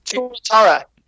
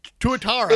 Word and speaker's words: Tuatara: the New Zealand Tora Tora. I think I'm probably butchering that. It's a lizard. – Tuatara. – Tuatara.